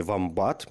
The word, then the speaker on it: вомбат: Австралийские звери вомбаты – по-моему, ударение правильно ставится на э, слово, на букву «о», хотя периодически я встречаю в какой-то живой речи наименование «вомбат», мне кажется, правильный все-таки «вомбат», это вот сумчатые эти эм, которые живут в Австралии.